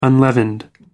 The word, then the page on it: unleavened: American English